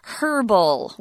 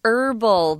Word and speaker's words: herbal: There are a lot of cooking shows here in Taiwan, and you'll hear the British cooks say herbal with an H sound at the beginning. But in America or the United States, for the most part, most people say herbal. They leave that H sound out.